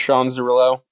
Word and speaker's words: Sean Zerillo: Yeah, so you can find me on Twitter at Sean Zerillo, S-E-A-N for Sean. A lot of ways to spell that, not C-H-O-N-E like Sean Figgins.